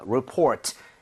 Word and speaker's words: report: Report, 이렇게 발음할 때 레가 아니라 report.